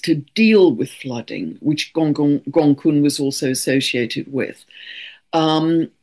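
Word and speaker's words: Gonggong: We also have the dwarf planet Gongong, which I squeezed in, which is spelt all one word, Gongong, pronounced Gongkun, 6 degrees of Pisces, very tightly conjunct the North Node, our collective soul's path of growth.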